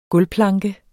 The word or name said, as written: gulvplanke